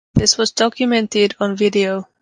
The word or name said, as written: documented